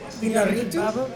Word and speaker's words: Iñárritu: Iñárritu? – Yeah.